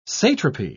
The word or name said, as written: satrapy